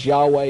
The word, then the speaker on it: Yahweh: The King James calls it Jehovah, probably more properly pronounced as Yahweh.